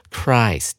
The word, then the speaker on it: Christ: 欧美人士他们都知道 Christmas 这个单字隐含了一个字根，叫做 Christ，C H R I S T，也就是耶稣的意思。但大家特别注意，单单是耶稣的时候，我们称之为 Jesus Christ。或者是 Christ，注意它那个母音 i 是念 i 的，但是放到 Christmas 的时候，它就不念成 Christmas，而是念成 Christmas 了。